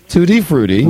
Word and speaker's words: Tutti Frutti: You got to work on your pronunciation of Tutti. – Okay, so it'd be Tutti Frutti.